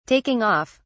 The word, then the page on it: taking off: British English